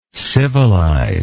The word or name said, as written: civilizer